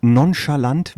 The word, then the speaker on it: nonchalant: Das Fremdwort nonchalant, habe ich das richtig ausgesprochen? – Nonchalant heißt das doch. Ja.